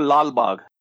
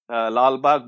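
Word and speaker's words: Lalbagh: Yeah, it is called Lal Bagh, Lal Bagh Botanical Garden.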